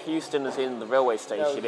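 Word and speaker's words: Heuston: Yeah, but they then don't pronounce the H, so it becomes Houston.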